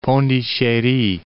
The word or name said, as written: Pondichery